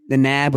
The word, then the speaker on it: Knab: Yes, for listeners out there who might not know who we're talking about, the name is spelled K-N-A-B. – A-B-B. – And the K is silent.